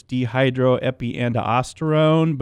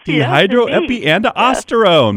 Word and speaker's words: dehydroepiandrosterone: Can you pronounce that for me? Because I've tried it. I think it's dehydroepiandrosterone, but is that right? – You're as close as I can get there.